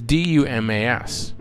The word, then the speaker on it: Dumas: Well, it's D U M A S, but he's French, so it's pronounced Alexander Dumas.